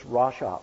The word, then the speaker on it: rasha: And the word is rasha, R-A-S-H-A, rasha.